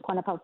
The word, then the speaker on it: Kwanapa: Just to say that again, my name is Kwanapa Phivnil.